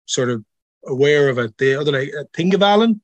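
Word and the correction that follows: Þingvellir: So most people say Thingvellir, like Thingvellir.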